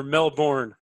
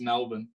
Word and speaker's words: Melbourne: And how do you pronounce it? Is it Melbourne or Melbourne? – It's Melbourne.